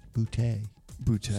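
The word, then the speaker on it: Bigboote: I just remembered John Big Booty. Boutte. – Sorry, yes. – I mispronounced that.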